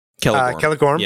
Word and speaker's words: Celegorm: And in this passage here that I highlighted, is it Celegorm? Am I pronouncing that right? – Celegorm.